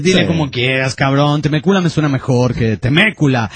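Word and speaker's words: Temecula: La ciudad de donde escribe el Polichafa no se pronuncia Temécula, sino Temécula.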